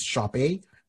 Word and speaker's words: Sope: So I know the name is pronounced Shop A, but it's spelt S-O-P-E.